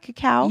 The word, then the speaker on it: cacao: Did I say that right? right, cacao? – You did.